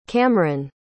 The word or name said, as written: Kamron